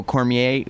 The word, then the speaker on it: Cormier: So when you see Jon Jones and Daniel Cormier, how do you Cormier.